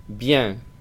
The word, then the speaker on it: bien: en la terminación IEN, es como una excepción, se pronuncia parecido al español. Es con la nasal de la E, es decir, que es parecido a bien. Ellos nasalizan y dicen bien.